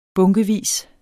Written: bunkevis

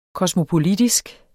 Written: kosmopolitisk

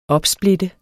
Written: opsplitte

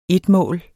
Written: etmål

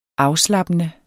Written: afslappende

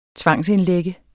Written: tvangsindlægge

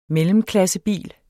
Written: mellemklassebil

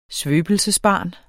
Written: svøbelsesbarn

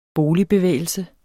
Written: boligbevægelse

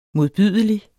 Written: modbydelig

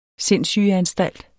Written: sindssygeanstalt

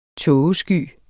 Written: tågesky

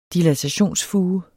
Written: dilatationsfuge

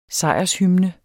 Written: sejrshymne

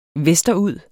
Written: vesterud

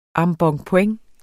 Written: embonpoint

